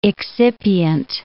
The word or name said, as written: excipient